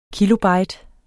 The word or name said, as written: kilobyte